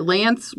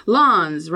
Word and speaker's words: Lanz: L-A-N-Z. Now, I always pronounced it Lance, like rhymes with pants. But when I saw the gentleman in Nashville a few years ago, I learned that he pronounces it Lons, rhymes with Johns.